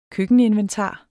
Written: køkkeninventar

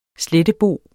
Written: slettebo

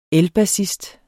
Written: ELBASSISTEN

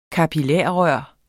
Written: kapillarrør

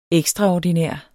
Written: ekstra-

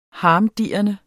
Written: harmdirrende

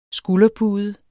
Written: SKULDERPUDE

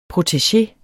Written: PROTEGEER

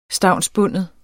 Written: stavnsbunden

